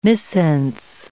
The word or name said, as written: missense